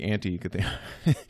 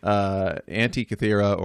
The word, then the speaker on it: Antikythera: And so there's no Kythera mechanism and this isn't the Anti-Kythera. So it's a Greek island, Anti-Kythera or Antikythera, however British you would like to pronounce it, but Antikythera mechanism.